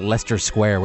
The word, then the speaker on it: Leicester: Because that's one of those stupid things like Leicester Square, where where they've just decided to ignore letters.